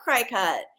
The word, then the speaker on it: Cricut: But no, cricket is spelled C-R-I-C-U-T. – Oh. – And I had been pronouncing it cricket. And I was embarrassed when I was talking to her. And I'm like, I love cricket. And she's like, oh, it's actually cricket.